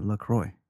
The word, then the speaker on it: LaCroix: Yeah, LaCroix. – If you go on their website, it says it rhymes with enjoy.